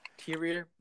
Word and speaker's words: Tyr: I'm pretty sure his name was Tyr. – Tyr, my bad on the pronunciation.